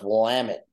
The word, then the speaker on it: Willamette: No, it's Willamette, damn it.